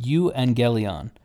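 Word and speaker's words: euangelion (εὐαγγέλιον): So in Greek, a gospel, the word for gospel is is pronounced euangelion, and eu eu simply means good and you ha- you have this uh, this root showing up in various places in English.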